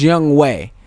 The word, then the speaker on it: Younghoe: But homie's name is Young Ho Koo, spelled Younghoe. Now, it's pronounced Young Way, but all I'm saying is, dog, if homie went to a predominantly black school, he would have got killed.